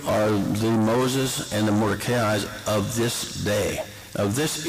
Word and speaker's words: Mordecai: Now, I mentioned Moses, and I mentioned, I pronounce it Mordecai, I mentioned those two figures.